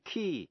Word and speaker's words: quay: A-C-C-U-R-A-C-Y, accuracy. Và precision, P-R-E-C-I-S-I-O-N. Cũng ôn lại những chữ dễ phát âm sai như là chữ key, Q-U-A-Y, nghĩa là bến tàu. Debt, D-E-B-T, nghĩa là nợ. Và receipt, R-E-C-E-I-P-T, là biên lai.